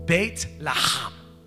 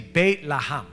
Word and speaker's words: Bethlehem: But if you look at where he was born, Bethlehem, it's pronounced, I'm going to jack it up because I know like I can't say it like the Hebrews, but it's pronounced Beit Laham. That was my best I could do, okay? But it's basically Beit Laham is how it's pronounced.